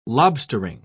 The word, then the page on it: lobstering: British English